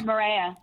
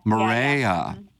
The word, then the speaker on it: Marea: Second, I was in the city last week and took my wife to, is it Maria? But it's spelled M-A-R-E-A on the south side of the park. – Oh, Maria. – Maria. Yeah, yeah.